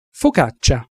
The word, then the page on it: focaccia: Italian